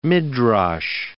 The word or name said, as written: midrash